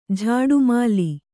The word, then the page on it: ಝಾಡುಮಾಲಿ: kannada